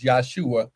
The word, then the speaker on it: Yahshua: Yahshua is how it would have been pronounced in Hebrew.